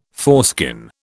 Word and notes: foreskin: British English

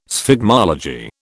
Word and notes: sphygmology: British English

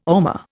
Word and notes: ormer: Indian

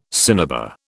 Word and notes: cinnabar: British English

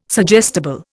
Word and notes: suggestible: Indian

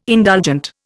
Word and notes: indulgent: Indian

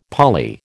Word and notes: Pali: American English

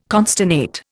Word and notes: consternate: Indian

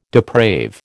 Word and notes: deprave: American English

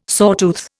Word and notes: sawtooth: Indian

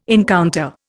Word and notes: encounter: Indian

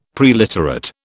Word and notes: preliterate: British English

